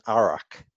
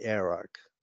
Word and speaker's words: Arak: You know, I grew up saying Arak, and then I found out it was actually the accent is on the first day and it's actually Arak.